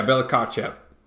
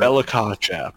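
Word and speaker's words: Bella-Kotchap: Yeah, Bella Kotchap. Did I say that right? – Sure.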